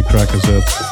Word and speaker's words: Crackazat: I'm not sure how to pronounce it. I always say Crackerzat. Flemish way.